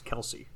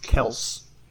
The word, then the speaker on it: Kelce: His last name is actually not pronounced Kelce.